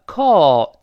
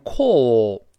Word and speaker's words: call: 好，那么 call 就是给某人打电话，英国人会读成 call，它有个撅嘴的哦。但在这儿呢，美国变成叹气，怎么说成啊？就是 call call，哎，懒惰的口型嘛。